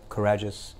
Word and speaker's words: Carajás: They've and they mine um, in an area Carajás, I think that's how you say it.